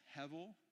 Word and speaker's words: hevel: And then it's actually pronounced hevel. And so it means vanity, but it literally means vapor.